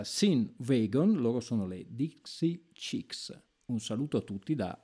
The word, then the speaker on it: Dixie Chicks: Eh, lo sapevo che non l'avrei detto, fatemelo ridire, sono le Dixie Chicks lo ridico, sono le Dixie Chicks,